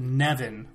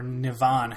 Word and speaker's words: Nevan: I never could get my hands wrapped around it that well, but Nevan, or Nivan, however you want to pronounce it, N-E-V-A-N, which is an electric guitar that he uses that turns into a scythe for different combos.